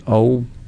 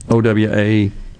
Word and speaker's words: OWAI: Tell us about how do you pronounce it? OWA? – OWAI.